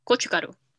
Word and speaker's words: gochugaru: So it was banchan, made with asparagus, with, am I going to say it right? Gochugaru? – Gochugaru.